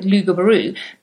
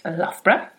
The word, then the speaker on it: Loughborough: For those of you who are American, you might be tempted to call it Loughborough, but actually it's pronounced Loughborough.